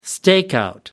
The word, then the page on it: stakeout: American English